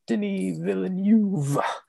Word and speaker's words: Denis Villeneuve: You couldn't pronounce Deneview Villeneuve. I combined his fucking name. Um, number three, Ava DuVernay for 13th two, Barry Jenkins for Moonlight, and number one, Denis Villeneuve for Arrival.